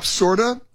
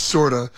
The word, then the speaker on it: Corda: It's called Pop Sorda. It's spelled C-O-R-D-A, but pronounced with like an S, sorta.